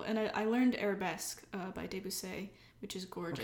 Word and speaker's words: Debussy: I have no idea Debussy. Who are you? No, it's Debussy. He was French. – I don't know. I was like, I always kept thinking of, like, a French person being like, Debussy.